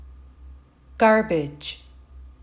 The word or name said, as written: garbage